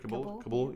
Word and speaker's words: Kabul: Kabul, can I say that right? Kabul, yeah.